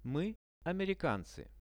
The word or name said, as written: my americancy